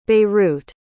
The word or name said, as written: beirut